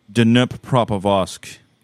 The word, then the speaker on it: Dnipropetrovsk: Yeah, it's called Dnipropetrovsk.